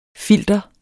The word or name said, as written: FILTERETS